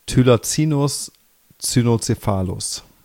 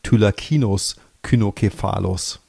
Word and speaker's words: Thylacinus cynocephalus: Oder so wie ich es damals noch gelernt habe, Thylacinus cynocephalus. Da wurde das C noch wie ein K ausgesprochen, aber ich glaube, das ist nicht unbedingt die Standardnorm.